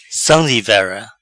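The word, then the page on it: Sam divera: Patua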